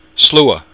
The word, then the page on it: slua: Irish